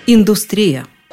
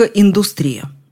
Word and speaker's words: индустрия: Орфоэпический словарь под редакцией Аванесова в качестве правильного варианта рекомендует индустрию, но рядом стоит индустрия с пометой «допустимо устаревающая».